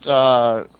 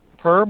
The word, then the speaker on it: Per: I want to thank my host, Per Malm. – Who? – Per Malm. – How do you spell that? – His first name is P-E-R.